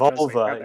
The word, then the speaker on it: Volver: Just, it doesn't mean Volver, it's just Volver.